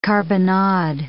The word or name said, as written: carbonade